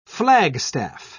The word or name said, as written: flagstaff